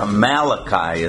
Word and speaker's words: Malachi: Malachi, or Malachi as we pronounce it.